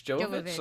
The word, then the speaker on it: Jovovich: They're making a Monster Hunter movie with Mila Jovovich. – Jovovich.